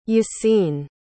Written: Yasin

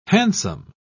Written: hansom

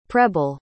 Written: prebble

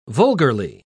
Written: vulgarly

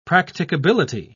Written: practicability

